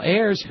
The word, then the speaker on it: Ayers: who's," and it's got a pronouncer in there, A-I-R-S, "Bill Ayers, whose organization bombed the U.S.